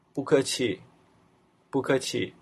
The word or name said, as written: bukeqi